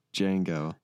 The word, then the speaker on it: Django: Starts with a silent D. – How many movies do you know that start with a silent D? – Django.